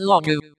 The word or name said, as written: princess